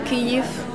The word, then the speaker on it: Kyiv: Les voies errantes de Kyiv, actuellement en France, la prononciation n'est pas correcte. Kiev, c'est russe.